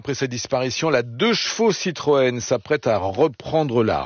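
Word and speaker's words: deux-chevaux: 2-chevaux.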